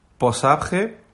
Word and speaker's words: Posavje: Eslovenia tiene tres grandes regiones vitivinícolas que voy a intentar pronunciar, que se llaman Primorska, Posavje y Podravje, uh-huh. ¿Vale? La J es con una I.